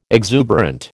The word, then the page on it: exuberant: American English